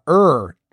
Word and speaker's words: err: Err, rhymes with hair. No, it rhymes with her.